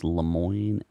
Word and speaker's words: Lemoine: I don't know if it's pronounced Lemoine, L-E-M-O-I-N-E, was put on leave after speaking out saying that a chat bot.